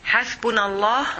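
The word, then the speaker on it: hasbunallah (حسبنا الله): And how do you say that one again in Arabic? – In Arabic, hasbunallah.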